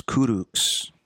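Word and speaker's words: Kurucs: Rodion Kuritz, is that pronounced correctly? – Rodion's Kuruks.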